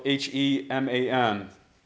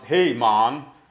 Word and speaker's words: Heman: It's actually pronounced Hey-Man, you know, in the Hebrew. So that's more accurate. Hey-Man. Maybe not with that kind of twist, but Hey-Man is how it's pronounced.